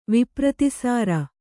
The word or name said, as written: ವಿಪ್ರತೀಸಾರ